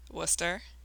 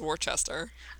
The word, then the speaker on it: Worcester: How do you say Worcester? – Worcester. – Okay. – But when I moved here, I thought it was Worcester.